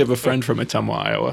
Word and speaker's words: Ottumwa: I apologize because I'm pretty sure I butchered the pronunciation. – I have two friends from Ottumwa. Actually, no, you pronounced it right.